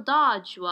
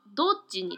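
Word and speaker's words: Dodge: あの、日本語のこのカタカナでダッジってなってるんですけど、英語の発音のダッジはドッジに近いですね。なので、このダッジ・ g e 1500 のダッジは、えー、ドッジボールとかのドッジと一緒です。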